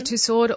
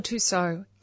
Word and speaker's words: Tussaud: You can say Tussaud or Tussaud. It's absolutely correct either way.